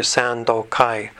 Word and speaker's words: Sandokai: And I'll use the Japanese pronunciation, even though it's a Chinese text. And the Japanese pronunciation is, as I mentioned earlier, Sandokai.